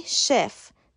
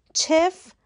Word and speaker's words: chef: لغت اول C e به معنی سراشپز تلفظ صحیح شف چف غلطه